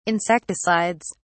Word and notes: insecticides: British English

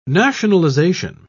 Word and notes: nationalisation: British English

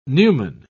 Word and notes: numen: British English

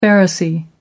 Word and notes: pharisee: American English

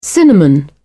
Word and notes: cinnamon: British English

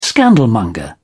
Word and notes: scandalmonger: British English

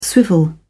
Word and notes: swivel: British English

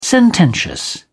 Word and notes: sententious: British English

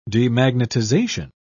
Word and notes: demagnetization: British English

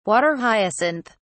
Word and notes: water hyacinth: British English

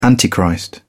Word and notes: antichrist: British English

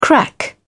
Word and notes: crack-up: British English

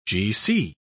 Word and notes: gc: British English

